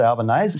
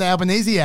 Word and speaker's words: Albanese: But that might be because he pronounces easy A's. Right? – Maybe. – And so he just thinks it rhymes with, it won't be A's under Albanese.